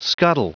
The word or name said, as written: scuttle